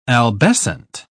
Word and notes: albescent: British English

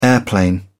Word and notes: airplane: British English

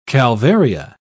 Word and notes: calvaria: British English

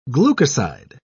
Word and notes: glucoside: British English

